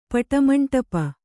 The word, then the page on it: ಪಟಮಂಡಪ: Kannada